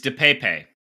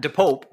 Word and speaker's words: DePape: Anyway, how's your pronunciation guide on this one? – It's DePape. – Nice. – No, I'm sorry. No, it's DePape. It's DePape. I'm sorry. – DePape. – Yeah, DePape. – Not DePope, but DePape.